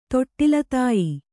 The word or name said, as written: ತೊಟ್ಟಿಲತಾಯಿ